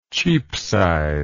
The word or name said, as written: Cheapside